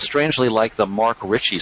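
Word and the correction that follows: Marc Rich: If, am I getting his name right, or whatever the guy was? – Mark Rich. Yeah. – Mark Rich, yeah.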